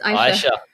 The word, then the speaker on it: Aisha: It's Aisha.